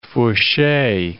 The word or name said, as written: fourchee